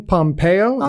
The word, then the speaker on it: Pompeo: Grey's Anatomy star Ellen Pompeo. Is that how you say it? – Yeah, we know.